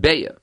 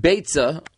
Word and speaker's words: Beitzah: We can bring a raya from the Rabbeinu Hanano right over here in the beginning of the Masechta that he definitely pronounced it with the tzaddik, because the Lashon of the Rabbeinu Hanano, he has a poem in the beginning of the Masechta, and he says as follows, Aschil Maseches Beitzah Be'ezras G'doyel Ha'etzah. It wouldn't be a rhyme if he would have pronounced it Beya.